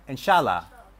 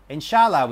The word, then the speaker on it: Inshallah: Did I say it right? Inshallah. Inshallah, we say here in Egypt.